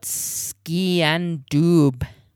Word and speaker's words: sgian dubh: It's spelt skean dub. – S-G-I-A-N-D-U-B-H. Grandad repeated the Gaelic word slowly.